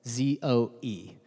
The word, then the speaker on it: zoe: The word is Zoe, Z O E, uh, properly pronounced Zoe.